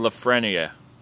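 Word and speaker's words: Lafrenière: I think I said it right, Lafreniere. – French. Yeah, Lafreniere. Yeah, something like that and then Quinton Byfield, Alexander Holtz, Lucas Raymond, and Jamie Drysdale.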